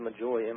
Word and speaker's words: magoi: Well, the word I've heard the word magoi, M-O, I believe I pronounced it correctly, M-A-G-O-I, from where we get our word magician, so magi, magician.